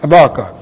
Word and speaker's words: Habakkuk: Habakkuk, I know some people pronounce Habakkuk, but Habakkuk.